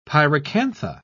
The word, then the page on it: pyracantha: British English